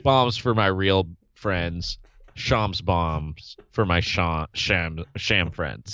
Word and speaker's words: Shams: Shams. It rhymes with bombs.